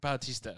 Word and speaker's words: Bautista: How do you say it? Barusta? – Bautista. – Barusta. – I like Barusta. – And there's no R in there.